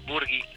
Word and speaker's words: Burghi: Buenos días, sí, Burgui. – Ay, pensé que te había pronunciado mal el apellido.